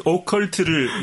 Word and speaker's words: 오컬트: 아, 선배도 발음이 잘 안 될 때가 있네요. – 뭐라고 그랬죠? – 아까 오컬터 그랬어요. 오컬터.